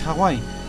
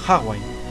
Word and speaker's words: Hawái: Si en Operación Trueno se referían a la OTAN como NATO, en Solo se vive dos veces pronuncian incorrectamente Hawái, llamándolo Hawaii.